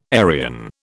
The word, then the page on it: Arian: British English